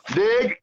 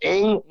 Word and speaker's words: Ng: So I'm sitting in the doctor's office, and there's an Asian man sitting there, whatever, and the receptionist, Lonnie Anderson, goes, "Nig, the Nig family," just some stuff. It just screams it over the whole thing. It's pronounced "ing."